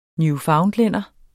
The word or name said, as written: newfoundlænder